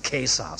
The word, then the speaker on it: Cäsar: Jeder, der heute Latein lernt, weiß wieder, dass man Cäsar sagen muss. Wir haben damals drüber gelacht, aber heute heißt das wieder der Cäsar. Das C muss mit K ausgesprochen werden.